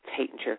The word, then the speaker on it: Taittinger: And Claude Tatinger, because the Champagne Tatinger, and the Tatinger name is pronounced many different ways. It's originally an Austrian name, so it would have been Taittinger, and in France it's called Tatinger. In the United States we say Tatinger or Tatinger, and Claude Tatinger always said it doesn't make any difference how you pronounce it so long as you reach for the bottle.